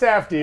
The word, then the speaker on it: Safdie: The Softy Brothers? Make it a Blade movie? That would be intense. – I believe it's pronounced Safdie. Yeah, Safdie.